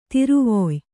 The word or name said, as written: ತಿರುವೊಯ್